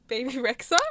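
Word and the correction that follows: Bebe Rexha: Oh, BB Rexha.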